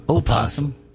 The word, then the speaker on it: opossum: I think the correct pronunciation is actually opossum.